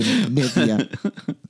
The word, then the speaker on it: Nivea: I'm on Pod Nivea. That's the French pronunciation, Nivea.